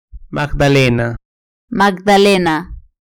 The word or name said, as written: magdalena